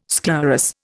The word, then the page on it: sclerous: Indian